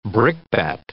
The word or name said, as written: brickbat